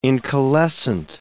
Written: incalescent

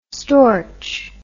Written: storch

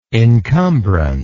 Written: incumbrance

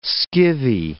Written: skivvy

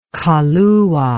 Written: Kahlua

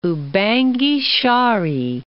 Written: Ubangi-Shari

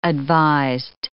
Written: advised

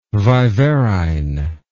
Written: viverrine

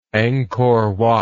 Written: Angkor Wat

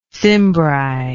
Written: cimbri

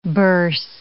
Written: birse

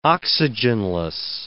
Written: oxygenless

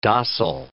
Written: dossel

